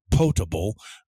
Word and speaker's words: potable: The word is, it looks like it has the word pot in it, la olla, but it's pronounced potable.